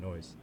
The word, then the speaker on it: Noyce: Oh, my name is DJ Noyce. My real name is Neo, but I just put Neo Is. – Mm. – And I just pronounce it Noyce. Like I am Neo Is.